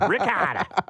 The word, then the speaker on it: ricotta: I don't eat it, but I definitely call it ricotta. It's like seven A's in it when I say it. Ricotta.